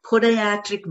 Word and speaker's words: Podiatric: And so this individual um, is um, board certified by American Board of Primary Podiat- Podiatric Medicine.